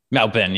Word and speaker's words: Melbourne: Melbourne, yeah. It's like M-A-L-B-E-N, even though, you know, it's spelled B-O-R-B-O-U-R-N-E. I think that's how you pronounce the Ben and the Born.